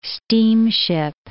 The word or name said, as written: steamship